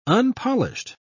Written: unpolished

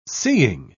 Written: seeing

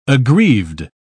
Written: aggrieved

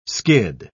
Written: skid

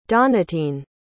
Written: donatien

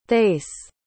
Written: thais

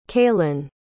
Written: kaylan

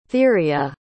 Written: theria

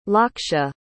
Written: Laksha

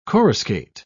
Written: coruscate

